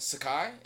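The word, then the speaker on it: Sacai: All right, so moving on from that disaster, we're going to move on to the brand Sakai. Is that how you pronounce it? – Yeah, that's how we're going to pronounce it.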